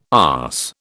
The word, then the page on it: arse: British English